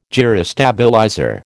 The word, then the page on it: gyrostabilizer: American English